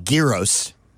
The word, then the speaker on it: gyro: Pronunciation gyro. – Of it? – Gyro. Gyro. Gyro. Gyro. Like gyroscope. – Yeah, when we were kids, we used to call it gyros.